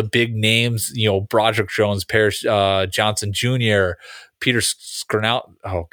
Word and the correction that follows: Skoronski: Skoronski, I think, is how you pronounce it.